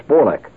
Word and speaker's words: Bolek: Now, I learned how to speak Hungarian from Géza, and I learned how to speak Polish from Bolek, which is more properly pronounced than Polish. It's Bolek.